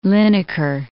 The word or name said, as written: Linacre